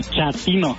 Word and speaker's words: chatino: Chapino. – ¿Chapino? – No, chatino. – Es como chato, ¿verdad? Chatino. – Sí, asunero como chato, chatino.